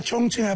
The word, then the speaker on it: Chongqing: Yeah, Chongqing.